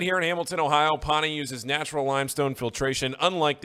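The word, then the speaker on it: Pahhni: We have Pawnee Water.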